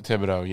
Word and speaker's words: Thibodeaux: Thibodeaux, I think that's how you say his name. – Thib- Thibodeaux, yeah.